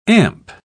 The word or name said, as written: amp